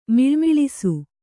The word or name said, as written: ಮಳಮಳಿಸು